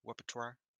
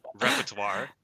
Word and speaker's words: repertoire: But yeah, you know, I hope that she does keep it in her repertoire. I'm saying it wrong, worse every time. repertoire. There we go. That was good.